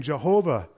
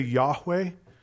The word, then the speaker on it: Yahweh: And so you might have heard the name Jehovah, and that's often the transliteration of YHWH. Modern scholars have said that it's more likely Yahweh is how to pronounce that.